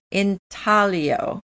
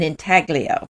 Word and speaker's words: intaglio: In Italian, it's pronounced intaglio, and the G is silent. But in English, we call it an intaglio.